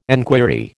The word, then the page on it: enquiry: American English